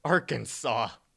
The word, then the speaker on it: Arkansas: and it's pronounced Consaw, Arkansas. How is Kansas over here, but in this state you pronounce it Kansas? – I totally agree.